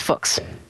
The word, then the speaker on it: Fuchs: I say rhymes with books, so it's Fuchs.